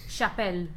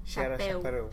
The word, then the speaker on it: Chapéu: Chapel. – Chapéu.